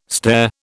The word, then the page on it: stare: British English